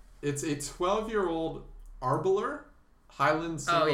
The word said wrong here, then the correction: Aberlour: And so Aberlour.